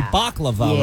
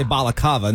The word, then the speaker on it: baklava: It's supposed to be baklava, right? But I always say balakava.